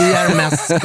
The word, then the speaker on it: Hermes: Och S-et i Hermes ska uttalas.